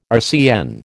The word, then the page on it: RCN: American English